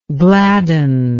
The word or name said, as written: gladdener